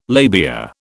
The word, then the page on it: labia: British English